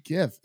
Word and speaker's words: GIF: Where my hard G boys at? – Right. I swear every single time that this happens, it's like, it's not that hard. It's GIF. It's GIF. – Graphical interchange format. Graph. Hard G. Graph.